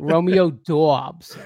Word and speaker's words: Romeo Doubs: Romeo Doubs. – Oh, Romeo Doubs. – I feel like that's how it's pronounced. Is that not right? – No, I mean, it is.